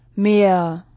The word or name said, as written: Meer